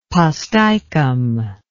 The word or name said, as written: posticum